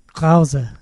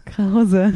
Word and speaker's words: Krause: But do you say, did I say the R? Like, how would you say it in German? Krause. Krause. – Krause.